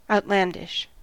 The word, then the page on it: outlandish: American English